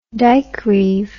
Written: dikereeve

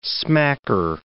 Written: smacker

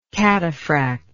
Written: cataphractic

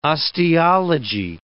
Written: osteology

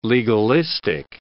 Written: legalistic

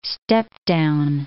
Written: step-down

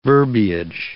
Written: verbiage

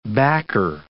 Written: backer

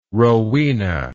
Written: Rowena

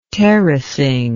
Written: terracing

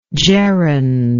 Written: gerundially

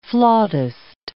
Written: flautist

